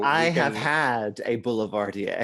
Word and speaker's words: Boulevardier: It's a Boulevardier. – Oh, what did I say? Belvedere? – Yes.